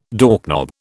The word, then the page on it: doorknob: British English